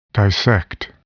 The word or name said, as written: dissect